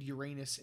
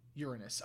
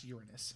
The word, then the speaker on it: Uranus: Gaia and Uranus in turn gave birth to Titan and the Cyclops. – Uranus. – Oh, it's pronounced Uranus. My bad.